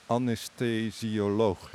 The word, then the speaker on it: anesthesioloog: Eh, uiteraard ook heel actief in de medische wereld als, en ik vind dat altijd een ingewikkeld woord, Marcus, anesthesioloog. Hè? Zeg ik het zo goed? – Ja, nou, we, hebben goed uitgesproken.